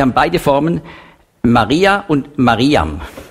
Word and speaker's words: Mariam: Und darum in alttestamentlicher Zeit hat man nicht ausgesprochen Miriam, wenn es geht um die Schwester von Mose, sondern man hat das ausgesprochen Mariam. Aber sprachgeschichtlich kam dann eben diese Änderung in den Jahrhunderten nach Christi Geburt zu Miriam.